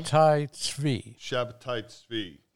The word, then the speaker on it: Shabtai Tzvi: Pronounce it for us correctly. – Shabtai Tzvi. Excellent.